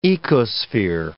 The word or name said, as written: ecosphere